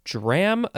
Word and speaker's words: DRAM: Next is dram again, all caps though. You can also pronounce it DRAM, which I feel like is probably more accurate.